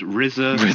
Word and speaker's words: RZA: Hi, it's pronounced Rizza.